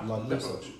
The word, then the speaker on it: liposuction: The majority of them are lip suction. Am I saying that right? – Liposuction. – Liposuction.